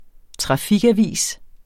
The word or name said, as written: trafikavis